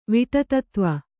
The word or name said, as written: ವಿಟತತ್ವ